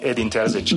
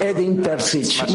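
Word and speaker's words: Edin Terzić: So, you can call me Edin Terzic. – Me puedes llamar Edin Terzic. Muy sencillo, es mucho más fácil que en euskera, así que es fácil pronunciarlo.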